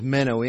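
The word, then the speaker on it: meno (μένω): It's the Greek word is meno, M-E-N-O, long O, meno.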